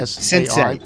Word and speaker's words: sensei: Is that how that word is pronounced? S-I-N-S-A-I? – Sensei. It's pronounced sensei. Yes.